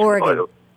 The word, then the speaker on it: Oregon: What is the correct pronunciation? – Oregon. Oregon. Yeah. – As opposed to Oregon.